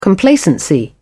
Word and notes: complacency: British English

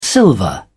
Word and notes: silver: British English

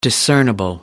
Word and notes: discernible: American English

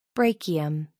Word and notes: brachium: American English

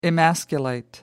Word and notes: emasculate: American English

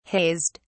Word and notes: hazed: British English

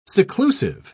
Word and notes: seclusive: British English

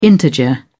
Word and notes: integer: British English

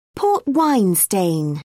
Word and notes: port-wine stain: British English

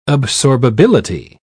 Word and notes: absorbability: British English

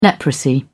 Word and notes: leprosy: British English

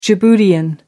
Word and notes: djiboutian: American English

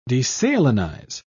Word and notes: desalinize: British English

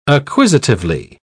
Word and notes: acquisitively: British English

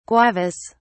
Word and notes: guavas: British English

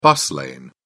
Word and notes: bus lane: British English

